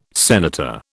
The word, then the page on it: senator: British English